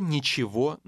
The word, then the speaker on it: ничего: The Russian G in the last two words will sound like V. – Не было ничего нового.